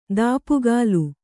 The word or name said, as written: ದಾಪುಗಾಲು